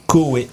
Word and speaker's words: Cauwet: Voilà, au 6 rue Coué. Coué, ça s'écrit C-A-U-W-E-T.